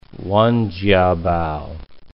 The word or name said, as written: Wen Jiabao